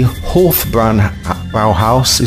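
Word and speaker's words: Hofbräuhaus: Thus, the Hofbräu Bauhaus is pronounced with a long O, as in holy.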